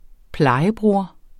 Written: plejebror

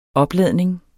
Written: opladning

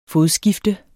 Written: fodskifte

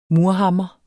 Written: murhammer

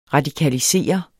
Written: radikalisere

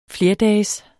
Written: flerdages

